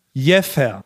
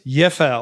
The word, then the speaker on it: Jever: Also die Stadt, geschrieben J-E-V-E-R, wird tatsächlich gesprochen Jeffa. – Nein. – So wie es ursprünglich mal vor 100 Jahren einer schon gesagt hat. – Jeffa. – Ja, die Stadt selber wird Jeffer ausgesprochen. Das Bier aber tatsächlich Jever mit W, wie Wilhelmine.